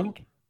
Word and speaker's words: Clegg: Clegg. Mark Jessen. His name is spelled C-L-E-G-G. – Ooh. – Ooh. – Ooh, like egg. – No, like Clegg.